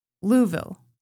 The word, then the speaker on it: Louisville: That it's actually pronounced Louisville. – Louisville. – Louisville. – Louisville. – Louisville. Two syllables.